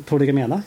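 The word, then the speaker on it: Prolegomena: Uh, Proligomena, jag sa det rätt tror ja, jag. Jättebra.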